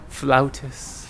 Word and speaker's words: flautist: How do you pronounce it? – Flautist. – Flow. – Flautist. – Flautist. – Like a beautiful flower.